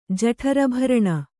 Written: ಜಠರಭರಣ

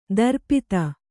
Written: ದರ್ಪಿತ

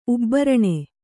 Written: ಉಬ್ಬರಣೆ

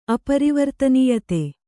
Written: ಅಪರಿವರ್ತನೀಯತೆ

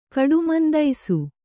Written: ಕಡುಮಂದೈಸು

